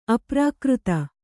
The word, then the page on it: ಅಪ್ರಾಕೃತ: aprākřta